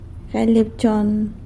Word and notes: galy abchon: sanlucasquiavini

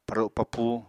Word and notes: paro papúu: Apatani

